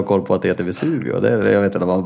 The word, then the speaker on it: Vesuvio: märkliga uttalet att han, att han uttalade, uttalade Vesuvio. Vesiluvio. Va? När jag frågade Nej. vilka pizzor de hade. Då sa han att de hade en Vesiluvio. Det tycker jag var märkligt.